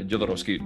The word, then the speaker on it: Jodorowsky: Uh, yeah, Jodorowsky.